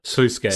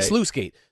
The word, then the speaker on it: sluice gate: But you'll just like, you'll roll into like Lady Dimitrescu's castle or like the Slice Gate or Sluice Gate. I don't know how you pronounce that word. – Sluice Gate. – Sluice Gate.